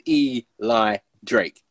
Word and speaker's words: Eli Drake: So basically, there's this wrestler called Eli Drake. That's how he pronounces his name, oh. like that.